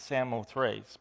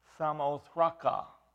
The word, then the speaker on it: Samothraca: Now, I know that some, when you look at that, you want to pronounce it Samothrace, but in the Greek, it's Samothraca.